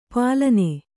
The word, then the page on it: ಪಾಲನ: pālana